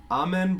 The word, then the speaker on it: Eamon: No, he says pronounced like Amen. Amen Breen.